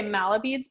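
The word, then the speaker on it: mala beads: But once you keep going, and that's why I loved what you said about, is it Malabeads? I've been saying it wrong this whole time. Okay. Malabeads. Perfect. Now I know.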